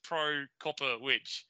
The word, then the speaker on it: Prokopowicz: I got it sent to me phonetically as three words. So it's Prokopowicz.